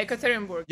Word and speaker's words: Yekaterinburg: Yekaterinburg? – Yeah, Yekaterinburg.